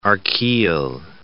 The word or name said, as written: archaeal